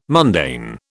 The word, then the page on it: mundane: British English